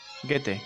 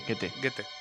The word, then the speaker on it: Goethe: ¿Cómo se pronuncia? ¿Goethe? Get. – Goethe, Goethe.